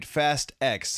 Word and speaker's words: Fast X: Yes, I said it right. Fast X.